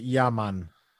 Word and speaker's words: Yemen: Yemen. – It was. No. Yeah, man. – That's Yaman. – Different. – It's pronounced Yaman.